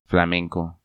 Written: Flamenco

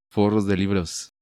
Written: Forros de libros

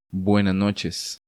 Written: Buenas noches

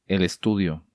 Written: El Estudio

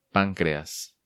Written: Pancreas

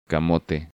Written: Camote

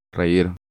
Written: Reir

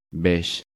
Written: Beige

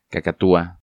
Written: Cacatua

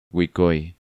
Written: Guicoy

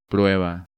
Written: Prueba

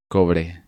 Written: Cobre

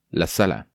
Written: La Sala

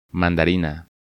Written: Mandarina

